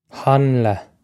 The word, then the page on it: hanla: Irish